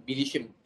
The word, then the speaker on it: bilişim: Oh yes, the I there is, it's a Turkish word, so it doesn't mean much in English, but and that bilişim is that word.